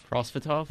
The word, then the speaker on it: Prosvetov: Ivan Prosvetov. – Prosvetov? – Yeah, Prosvetov. – Sounds right.